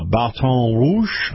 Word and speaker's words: Baton Rouge: And then the lower part of Louisiana to include New Orleans and I-12, Baton Rouge. I like saying it that way, Kyle, because that's the French pronunciation.